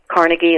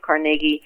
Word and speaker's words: Carnegie: Carnegie is how I pronounce it. Carnegie, other people pronounce it.